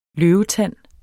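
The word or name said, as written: LØVETANDEN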